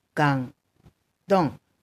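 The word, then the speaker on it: 感动: で、えっと、もう一つ言ってましたけど、感動っていう日本の言葉と全く中国も漢字も同じで、発音がガン、ドン、ガンドン。字は同じ。ガンドン。だそうです。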